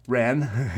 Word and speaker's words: Rennes: How do you say that properly? – Rennes.